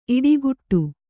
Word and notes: ಈಡುಗೋಡೆ: īḍu gōḍe

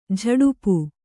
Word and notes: ಝಡಪ: kannada

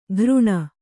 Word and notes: ಘೃಣ: Kannada

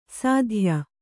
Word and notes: ಸಾಧ್ಯ: sādhya

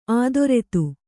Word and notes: ಅಧರಿತ: kannada

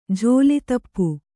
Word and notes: ಝೋಲಿತಪ್ಪು: jhōli tappu

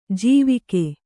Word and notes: ಜೀವಿಕೆ: jīvike

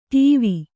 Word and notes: ಟೀವ: ṭīva

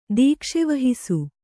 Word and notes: ದೀಕ್ಷೆವಹಿಸು: Kannada

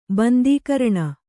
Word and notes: ಬಂದೀಕರಣ: Kannada

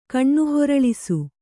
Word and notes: ಕಣ್ಣುಹೊರಳಿಸು: kannada

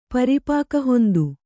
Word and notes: ಪರಿಪಾಕಹೊಂದು: Kannada

